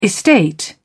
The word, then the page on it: estate: British English